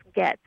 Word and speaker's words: Gaetz: Is that correct? – Yes, G-A-E-T-Z. I think it's pronounced Gaetz, but close enough. Okay, Gaetz.